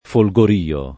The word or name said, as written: folgorio